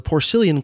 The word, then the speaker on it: Porcellian: Whereas the Porcellian Club with the soft C,